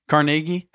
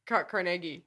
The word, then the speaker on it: Carnegie: Carnegie? How do you say it? – Carnegie.